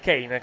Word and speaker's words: Kane: Intanto rivediamo la caduta da parte di Ken, di Kane, chiedo scusa, Ken è un altro giocatore. – Lapsus è strano questo, No, già stamattina avevo problemi con la pronuncia, cioè, mi ricordavo che c'era... Ricordavo che c'era Kane in campo, mi viene naturale pronunciarlo Ken.